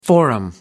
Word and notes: forum: American English

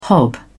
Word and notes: hob: British English

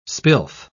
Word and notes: spilth: British English

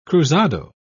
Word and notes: cruzado: British English

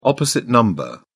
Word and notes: opposite number: British English

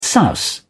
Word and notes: souse: British English

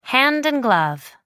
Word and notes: hand and glove: American English

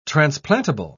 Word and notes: transplantable: British English

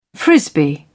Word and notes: frisbee: British English

